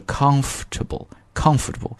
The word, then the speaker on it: comfortable: 那么这个 comfortable 呢，在读的时候注意啊，后面的这个 fort 这个 or 一般我们就不发音了，直接是 comfortable。